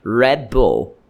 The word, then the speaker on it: Red Bull: Na verdade, a pronúncia correta desse energético é Red Bull, Red Bull, Red de vermelho e Bull de touro. Não é Bull e não é Red, porque Red é cabeça, meu amigo.